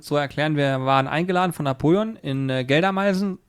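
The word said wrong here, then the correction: Geldermalsen: ist richtig ausgesprochen? – Geldermaßen, ja.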